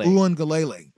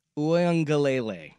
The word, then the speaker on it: Uiagalelei: Am I saying that right? – Uiagalelei. – Dang it. – Just say DJU. – No, I'm going to learn how to say his name. You know, yeah. One more time. – DJ Uiagalelei.